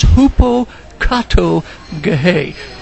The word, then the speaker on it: hupokatoge: That term is hupokatogahe. Now that's spelled H-U-P-O-K-A-T-O-G-E. And hupo means under, and kato, K-A-T-O, means down, down, beneath, and gehe refers to the Roman Empire.